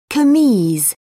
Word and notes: kameez: British English

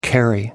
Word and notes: carry on: American English

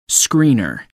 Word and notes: screener: American English

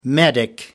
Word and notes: medic: American English

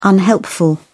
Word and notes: unhelpful: British English

